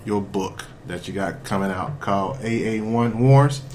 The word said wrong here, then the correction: AAI Wars: I'm saying that right? – AAI Wars. – AAI Wars. Okay, yeah, yeah.